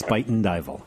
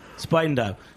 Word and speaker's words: Spuyten Duyvil: It's Spuyten Duyvil.